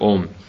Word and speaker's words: ом: И когда человек произносит этот звук, то а звучит где-то, так сказать, в животе внизу, у это уже ближе сердца, горло, м это звук, который звучит в голове, в высших структурах, так правильно произносится этот звук ом.